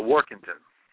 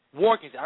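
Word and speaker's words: Warkentien: So the Knicks, seems that Mark, I keep mispronouncing it, Walkenstein? How do you say his name? – No, Workington. – Workington. I don't know why I keep saying it.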